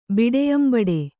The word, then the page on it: ಬಿಡೆಯಂಬಡೆ: Kannada